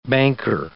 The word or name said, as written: banker